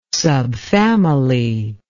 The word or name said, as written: subfamily